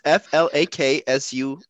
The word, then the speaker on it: flaccid: Let's not make me the re Flaccid. It's pronounced FLAKSUHD.